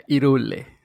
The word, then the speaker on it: Hyrule: Lo pronunciaste como nuestros compañeros del Hongo Verde España, Hyrule.